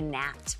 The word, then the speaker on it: gnat: So she said you were an annoying, like, a gnat, which, by the way, is spelled with a G.